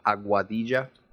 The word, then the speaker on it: Aguadilla: It's a Aguadilla.